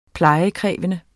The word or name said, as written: uplejet